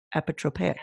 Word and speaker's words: apotropaic: Let's here's the proper de, proper pronunciation of apotropaic. Apotropaic. Apotropaic.